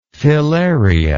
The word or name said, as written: filarian